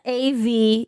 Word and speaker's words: Aventon: And that's Aventon. – Yeah, I hope I'm saying it right, you know, because I was saying it wrong on our last episode.